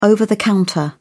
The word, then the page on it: over-the-counter: British English